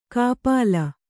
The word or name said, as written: ಕಾಪಾಲ